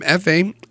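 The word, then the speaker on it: Efé: Speaking of new music, this is the latest from F.A., in all capitals, E-F-E, with an accent on the last E.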